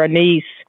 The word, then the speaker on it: anise: and you were not familiar with that star anise. I, I have always heard the word pronounced more commonly as anise, and I wonder if that might be more familiar to you.